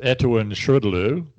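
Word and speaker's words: ETAOIN SHRDLU: Well, it's pronounced Etowin Shrudlu, but anyway, yeah, it's the first 12 keys on a Linotype keyboard, like going downwards.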